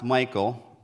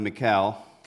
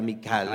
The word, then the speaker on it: Michal: I've also heard it pronounced Michael. – También se puede decir Michael. – But it sounds, looks, if you look at it in the interlinear in the Hebrew, it looks like Michal. – Y pero si vemos en el idioma hebreo, la pronunciación más correcta sería Michal. – I think in the Spanish too, right? It's more pronounced Michal, right?